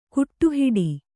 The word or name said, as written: ಕಡೆಹುಟ್ಟು